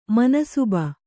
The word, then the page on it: ಮನ್ಸಬ್: mansab